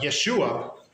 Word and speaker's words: Yeshua: Not literally Jesus with that pronunciation, but Yeshua, which is the Hebrew form of Joshua, of Jesus.